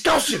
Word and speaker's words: Gaussian: Someone was saying it and Olu was like, it's Gaussian, it's Gaussian.